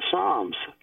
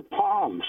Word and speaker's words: psalm: So he quoted something from the Psalms. And he didn't know enough to know that the word psalm, which starts with a P, which is a silent P, and he called it the palms.